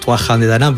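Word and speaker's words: Tuatha Dé Danann: Bueno, los irlandeses los pronuncian diferente, los Tuajan de Danann, pero sí, para nosotros los Tuatha de Danann, pues ellos realmente eran el penúltimo pueblo mitológico que habitó aquí la isla de la isla de Irlanda.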